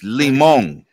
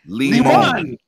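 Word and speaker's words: limón: It's Limon. Limon.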